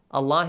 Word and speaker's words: الله: Allah is pronounced with an A sound.